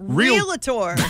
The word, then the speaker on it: realtor: No, there's no A in there. It's R-E-A-L-T-O-R. Okay. So it's there is an A in there. – No, no, not after the, not in the second part of it. – But then if you pronounced it that way, it would be realtor. – Yeah, you have to say real.